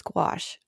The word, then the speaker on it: gouache: Uh, it is G O U C H E, I believe, and it rhymes with squash. And I didn't know about gouache until I was taking a class at Concordia.